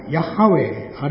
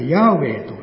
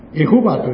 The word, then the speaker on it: ヤハウェ: 聖書の神の名はヤハウェ、あるいはヤーウェと読むんです。エホバと読むのは誤りなんです。